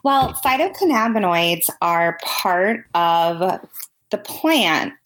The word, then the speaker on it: phytocannabinoids: And now with CV Sciences and working with phytocannabinoids.